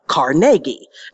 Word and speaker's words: Carnegie: And I said, oh, of course, I say it the right way. Carnegie. Not the way those New Yorkers think it is, because there is no hall like Carnegie.